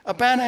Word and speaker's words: Abana: Abana and Pharpar.